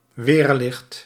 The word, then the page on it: éclair: France; /e.klɛʁ/